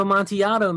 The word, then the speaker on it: Amontillado: Cask of Amontillado, man.